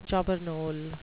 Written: jobbernowl

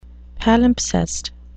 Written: palimpsest

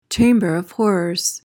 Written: chamber of horrors

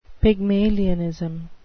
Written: pygmalionism

